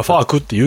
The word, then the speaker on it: FAQ: だいたい FAQ って書かれると、まず心の中でファックって読んでるよねっていう。口にするときはファークって言うけどさっていう。